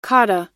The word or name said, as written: kata